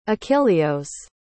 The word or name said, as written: achillios